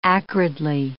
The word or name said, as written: acridly